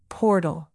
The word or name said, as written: portal